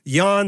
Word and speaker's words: Jan: But of Jan Sobieski, spelled J-A-N, it's pronounced Jan, Okay, of that 81,000 troops, 38,000 of them were on horseback.